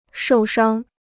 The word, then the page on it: 受伤: shòu shāng